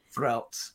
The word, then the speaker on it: Frelt: I don't know, am I Farrelt? – Frelt. It's supposed to be one syllable, and I cannot say his character's name, Frelt.